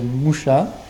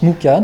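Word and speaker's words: Mucha: Celui-ci, donc, c'est d'après Moucha, qui se prononce Mouka d'ailleurs, qui est un artiste hongrois, donc du 19e.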